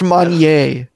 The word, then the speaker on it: Manger: Pret a Manger around my neck. – It's pronounced Manier, Rob.